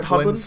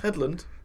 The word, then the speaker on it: Hedlund: Hedlund. – It's kind of fun to say, actually. – Hedlund. – Hedlund or Headlunge? – Headlunge.